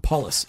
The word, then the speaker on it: Paules: So not as much emphasis at the end. Paules.